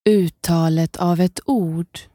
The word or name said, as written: uttalet av ett ord